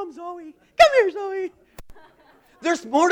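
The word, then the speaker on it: Zoe (ζωή): That word alive in the Greek is pronounced Zoe.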